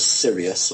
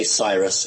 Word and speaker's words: Sirius: what you guys would pronounce as Sirius, or we say Cyrus, I think you say Sirius.